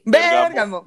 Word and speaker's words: Bergamo: que es todo Seguramente tiene como acento en la E es Bergamo. Bergamo, Bergamo, Bergamo está de huevo.